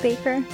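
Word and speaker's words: Bakker: It's spelled like Backer, but I guess it's pronounced Baker.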